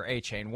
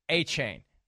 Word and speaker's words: Achane: There's a change in the pronunciation. There's a new pronunciation for Achane. Once we all figured out it's Achane, and we spent a lot of time in the studio on Sunday making sure everybody knew it was Achane.